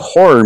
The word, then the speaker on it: horror: And actually, too, an entire month of horror movies in October. I'm sure that does a lot. – That's two syllables. Two syllables, boys and girls. Horror. Horror.